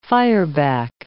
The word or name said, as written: fireback